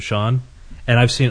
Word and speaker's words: Sean: They like to say seen for Sean.